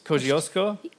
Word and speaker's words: Kościuszko: Imagine a great military hero like George Washington or Kazimierz Pulaski or, and I can't say his name correctly, Tadeusz Kościuszko? – Yes, very close.